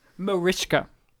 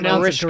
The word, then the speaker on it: Mariska: Mariska. – Yeah, but he spelled it correctly, I guess. – So you have to pronounce it Mariska. Correctly.